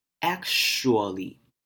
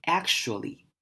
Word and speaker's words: actually: 那首先在这两个部分，第一个是 ack，就是 ac，念 ack，这边的 a 要发成短母音 a，actually。好，接下来后半段呢，tua 这边我们要把 tua 念成 sure，像是 sh 的声音，actually，念快一点，actually。